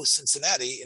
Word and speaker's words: Cincinnati: Now we know Cincinnati in English is spelled C-I-N-C-I-N-N-A-T-I. Two N's. Cincinnati. Cincinnati, right? The N is emphasized. There's a dogesh in the N. Cincinnati, right?